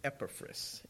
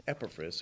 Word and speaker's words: Epaphras: I started Googling these names because I knew I was butchering them really bad. And I found a guy on the internet that he, it's funny because he'll give you the correct pronunciation, but he has a very heavy Spanish accent, so it's kind of funny. So this guy's name is Epaphras. Epaphras.